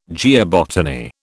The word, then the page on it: geobotany: British English